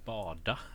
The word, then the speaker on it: BADA: det, är ju, alltså det är bara då, det, är, det är ju bada liksom. Ja, som att bada.